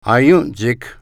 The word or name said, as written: Aientsik